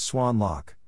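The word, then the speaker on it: Xuân Lộc: When the North Vietnamese army attacked Schwenlock, pronounced Swan Lock,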